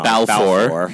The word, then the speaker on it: Balfour: Eric Balfour, I keep saying that wrong. – Balfour. Yeah.